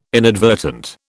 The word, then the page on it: inadvertent: British English